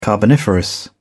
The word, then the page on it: carboniferous: British English